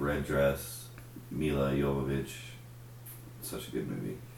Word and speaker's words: Jovovich: How do you say her name? – Jovovich. – Jovovich.